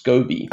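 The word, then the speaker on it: Skovbye: Luckily her last name is actually much easier to pronounce than it looks. – Oh, so I've made it way more difficult for myself. – yeah, so it's actually just pronounced Scobie. – Okay. That's nice. – The V is silent.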